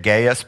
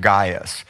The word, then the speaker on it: Gaius: A lot of people say it Gaius, but it's actually pronounced Gaius.